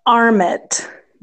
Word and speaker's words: Armitt: It's the Armitt Museum and Armpit, Library. Got it. Armpit. – A R M I T T. – Is Armitt. I, I, I still hear a silent P. Nope, no P.